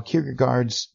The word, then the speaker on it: Kierkegaard: After hearing the recommendation from Wikipedia as to how Kierkegaard's name should be pronounced, I'm more confused than ever. Here's how it sounds. – Søren Åby Kierkegaard.